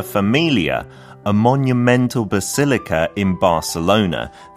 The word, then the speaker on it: Barcelona: But 그 C 소리가 약간 TH처럼 아, Barcelona. Barcelona.